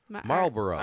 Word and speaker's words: Marlboro: Two syllables. – Yeah. – Marlboro.